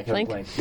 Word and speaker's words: Plank: So I read like that there's like even more like concrete ties with why Kevin Plank, is that how you say it? – Yeah, Kevin Plank.